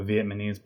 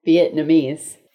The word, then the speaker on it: Vietnamese: A Vietnamese boyfriend? – You said it wrong. Vietnamese. – What did I say? – Vietnamese.